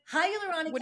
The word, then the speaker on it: hyaluronic: So can you tell us exactly how do you say it? Hydraulic? – Hyaluronic acid is a sugar molecule type that is found in your soft tissue.